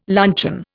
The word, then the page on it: luncheon: Indian